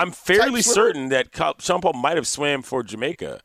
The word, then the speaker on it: Sean Paul: Yeah. That's how you have to say his name. – Sean Paul.